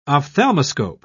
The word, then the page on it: ophthalmoscope: British English